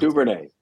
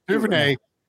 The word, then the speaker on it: Duvernay: I always mispronounce Duvernay. – Duvernay.